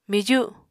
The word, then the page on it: miju: Apatani